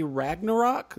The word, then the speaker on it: Ragnarok: But first, this week, Marvel and Disney unveiled the new Thor trailer, Thor 3 Ragnarok. Am I pronouncing that right? – Yes, you are.